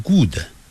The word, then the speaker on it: good: 这个 good 的发音很多人会发错，咱们中国人呢从黑龙江一直到海南岛念这个 G-O-O-D 啊，常常念成 good，它不是念成 good，是念 good，那个的的音很轻，几乎要念出来，但是又把它停顿。